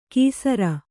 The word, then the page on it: ಕೀಸರ: kīsara